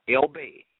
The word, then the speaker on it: Elbey: T-A-R-H-A-K-A, Amana, A-M-A-A-N-A, Elbey, E-L and then B-E-Y.